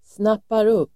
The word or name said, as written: snappar upp